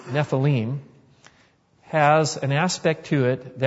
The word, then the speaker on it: Nephilim: It's common to hear people say Nephilim, but that's actually not the correct pronunciation according to Hebrew. It would be Nephilim.